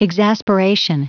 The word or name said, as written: exasperation